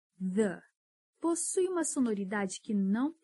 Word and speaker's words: the: Só que, diferente de algumas palavras com TH, se você reparar, o the também tem um leve som de Z the the Um erro muito comum na pronúncia do TH é pronunciar como se fosse F.